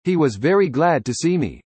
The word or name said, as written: glad